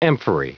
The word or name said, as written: amphorae